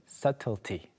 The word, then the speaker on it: subtlety: 자, 처음으로 나오는 것은 미묘함 또는 중요한 세부 요소들을 가리키는 subtlety. Subtlety. B가 발음이 되지 않아요.